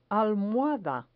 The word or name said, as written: almohada